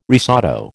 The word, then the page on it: risotto: American English